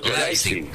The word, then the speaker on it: Leipzig: Leipzig, ¿cómo se dice? ¿Vos? – Leipzig. Con A. – Leipzig, ahí está.